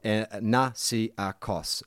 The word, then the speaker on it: Nasiakos: For context, my surname is Nasiakos. It's pronounced how it's spelt, Nasiakos.